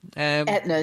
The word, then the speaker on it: Aetna: American health insurer, Aetna, I think is how you pronounce it. It's not how you spell it, but I'm going to pronounce it as Aetna. – Aetna is correct.